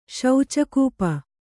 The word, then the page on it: ಶೌಚಕೂಪ: Kannada